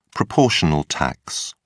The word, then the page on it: proportional tax: British English